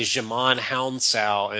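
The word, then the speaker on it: Djimon Hounsou: Especially since one of them is played by Jamon Hounsou in an early role.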